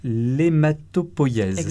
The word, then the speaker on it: hématopoïèse: Alors, dans le laboratoire auquel euh, tu es rattaché, vous vous intéressez à l'hématopoïèse. – Ça va, c'est bien prononcé.